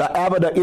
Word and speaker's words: Avodah: There's a religious and educational nonprofit corporation called AVIDA, the AVIDA Institute. It lists its address as the Service Master Headquarters. The word AVIDA, it's spelled AVODAH.